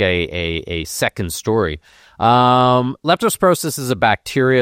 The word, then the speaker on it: leptospirosis: What, it's leptospirosis. I said that right, yes. – Yeah, leptospirosis, and I talked about this before the break because it was a problem in Jamaica.